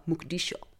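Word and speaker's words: Muqdisho: Mogadishu, precis, uttalas på somaliska Mogadishu. jag ber om ursäkt för det uttalet, men kallas också av somalierna för hamar.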